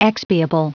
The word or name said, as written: expiable